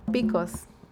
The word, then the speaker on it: picos: Picos.